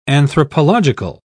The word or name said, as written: anthropological